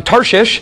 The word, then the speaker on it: Tarshish: He's trying to get to Tarshish. I don't know why we have to say it like that, but we do. That's the way it's spelled. Tarshish.